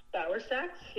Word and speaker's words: Bauersachs: This is Danny Pate interviewing uh, Jennifer Barasak. I hope I pronounced your last name right, Jennifer. – Bauersachs, yep. – Pretty okay. Close.